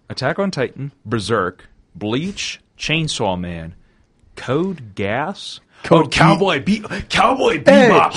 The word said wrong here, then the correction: Code Geass: It's Code Geass. He said Code Gas.